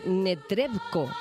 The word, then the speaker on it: Netrebko: Con la filosofía de nuestros martes, el fin de semana, tenemos que hablar de ese debut en el Real de Anna, a ver si lo digo bien, Netrebko. – Muy bien.